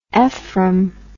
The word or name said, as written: efrem